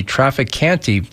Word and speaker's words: Trafficante: So Andrew writes this, great show, but please advise your guests that the Trafficante pronunciation of the name Trafficant is wrong and has been spread intentionally by law enforcement as a way of insulting the family. The final T is silent or nearly so, and correct pronunciation is quite nuanced.